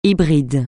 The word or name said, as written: hybride